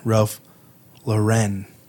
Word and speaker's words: Ralph Lauren: And let's say it correctly, Ralph Lauren.